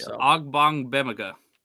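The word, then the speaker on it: Ogbongbemiga: It's Ogbong Bemiga. – Oh, is it? – That's how you say it.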